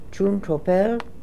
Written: Choon Tro Pel

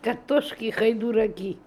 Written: Katoshkihydooraki

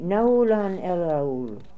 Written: Naoolan el Aool

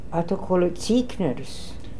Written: Autokolitzikners

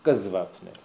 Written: Ksvatsnel

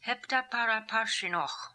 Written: Heptaparaparshinokh

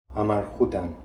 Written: Amarhoodan

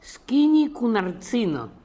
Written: Skinikoonartzino

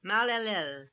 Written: Mal el Lel

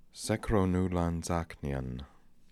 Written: Sekronoolanzaknian

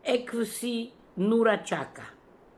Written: Epsi Noora Chaka

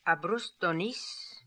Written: Abrustdonis

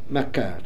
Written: Makar

